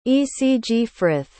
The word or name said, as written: ecgfrith